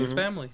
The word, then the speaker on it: Spy x Family: This is how you say it's just Spy X Family or Spy and Family? – Spy X Family.